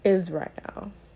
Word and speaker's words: Azrael: But he corrected me and he said that it's actually pronounced Israel.